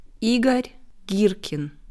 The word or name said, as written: Igor Girkin